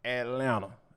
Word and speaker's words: Atlanta: And last but not least, our boy Anthony Edwards is straight up Atlanta. Yes, I meant to not pronounce that second T.